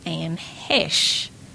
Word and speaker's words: Heche: Now, Susan Bergman had sisters, one of which is actress Anne Heche.